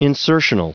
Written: insertional

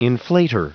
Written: inflater

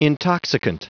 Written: intoxicant

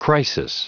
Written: crisis

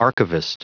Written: archivist

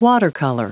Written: watercolor

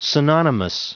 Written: synonymous

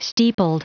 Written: steepled

